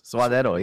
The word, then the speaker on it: suadero: Oh, suadero. Yeah, that's how you say it.